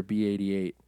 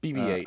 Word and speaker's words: BB-8: I keep forgetting if it's BB-8 or B-88. – BB-8.